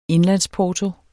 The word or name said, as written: indlandsporto